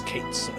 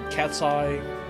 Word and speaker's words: Cait Sith: And the fact that Square actually came out and said the way you pronounce Cait Sith is Cait Sith. I don't know why people are saying cat's eye or Kate shit or or cat's Kind of stupid now, but yes.